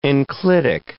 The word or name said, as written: enclitic